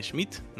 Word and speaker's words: Schmidt: Yo soy Dani Schmidt, S-C-H-M-I-D-T, así se escribe mi apellido.